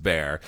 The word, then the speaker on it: Bexar: You know, you spell it B E X A R, but it's pronounced bear.